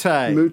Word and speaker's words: Moutet: Mute. Just remember Monet, Mute.